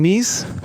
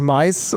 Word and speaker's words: Mise en place: Mise en Place kommt aus dem Französischen. Ja. Wird ausgesprochen Mise. Es kann passieren, dass ich manchmal noch Miese sage, einfach aus Gewöhnung. Es wird nicht Mais oder Mieze ausgesprochen, ja, habe ich alles schon gehört. Also Mies eigentlich.